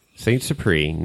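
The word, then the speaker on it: St. Supéry: Spell that, because that doesn't spell the way it sounds like. – It doesn't? So ST and then SUPERY. – That's right. – Saint Supreme, Napa Valley.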